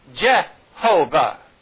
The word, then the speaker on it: Jehovah: In other words, they substituted the vowels that are in Adonai and put them between the letters of the sacred tetragrammaton, and you come up with Yehovah. See that? And now, and it's pronounced Jehovah.